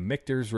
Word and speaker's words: Michter's: Michael said it right. Mark the tape. 33 minutes and 47 seconds. Michael said Michter's correctly.